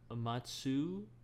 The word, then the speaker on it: Matsushita: Matsushita.